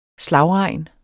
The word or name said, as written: slagregn